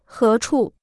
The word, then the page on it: 何处: hé chù